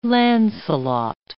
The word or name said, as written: Lancelot